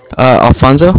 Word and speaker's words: Alfonso: Alfonso. – How do you spell that? – A-L-F-O-N-S-O. And then last name is Garza. G-A-R-Z-A.